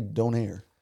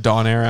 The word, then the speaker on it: Donair: How do you say don't air? – It's pronounced Donair, actually.